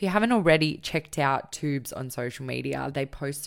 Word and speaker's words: Twoobs: So we're at Twoobs on Instagram. It's T-W-O-O-B-S. There's a silent W in there.